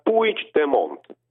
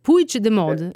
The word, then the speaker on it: Puigdemont: Mi dici tra l'altro come si, visto che tu sai bene il catalano, come si pronuncia il suo cognome? – Si pronuncia Puigdemont. – Puigdemont, è scritto Puigdemont, ma Puigdemont, vediamo se me lo ricordo, va bene.